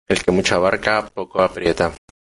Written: abarca

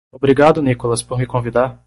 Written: convidar

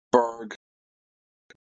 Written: Berg

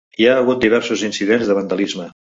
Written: hagut

